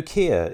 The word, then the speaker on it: Kier: Udo Kier is in this as well, if I've pronounced his surname.